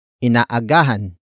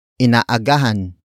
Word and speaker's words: inaagahan: Inaagahan na ni Joel ang pamimili, inaagahan na ni Joel ang pamimili.